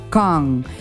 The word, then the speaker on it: Cannes: Se escribe Cannes, pero se pronuncia Cannes.